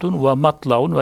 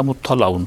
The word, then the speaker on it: مُطَّلَعٌ: لِكُلُّ اَيَتِنْ زَهْرٌ وَبَطْنٌ وَحَدٌ وَمَطْلَعٌ veya مُطَّلَعٌ da okunuyor.